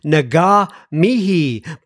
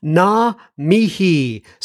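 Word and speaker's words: ngā mihi: It's NGAMIHI, and I think I've been pronouncing it Naga Mihi, but apparently the G is silent.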